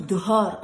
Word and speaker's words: dehors: Maman, arrête de dire dehors, on dit dehors, le H ne prononce pas.